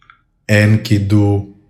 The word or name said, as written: ENKIDU